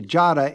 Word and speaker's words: Giada: I want to point out to our audience that although I'm pronouncing you correctly, Giada, it is spelled G I A D A. – Correct.